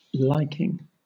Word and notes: liking: British English

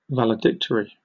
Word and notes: valedictory: British English

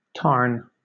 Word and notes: tarn: British English

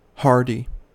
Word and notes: hardy: American English

